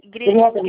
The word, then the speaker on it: Grieber: Grieber. Grieber. – Grieber. Ah, tá, desculpa, eu não sei como se pronuncia. – Alemão.